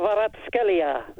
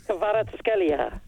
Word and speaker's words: Kvaratskhelia: Kvaratskhelia. There you go, that's how you say it. – Try it one more time. See, you couldn't do it twice. – Kvaratskhelia.